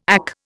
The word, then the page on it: AK: Indian